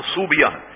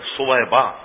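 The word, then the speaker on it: ثویبہ: یہ بھی ذہن میں رہے، بہت سے لوگوں نے اس کے تلفظ کو صوبیہ بنا لیا، بچیوں کے نام صوبیہ، یہ صوبیہ تلفظ غلط ہے، صویبہ یہ صحیح تلفظ.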